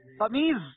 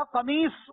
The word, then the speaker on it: قمیص: قمیض، اصل میں یہ تھا قمیص، سواد کے ساتھ.